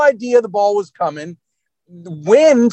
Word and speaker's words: Nguyen: I even talked to Ethan, the freshman long snapper, and it's spelled N-G-U-Y-E-N, which I was thinking Nagayan, but it's actually pronounced Wynn.